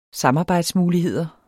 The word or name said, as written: samarbejdsmuligheder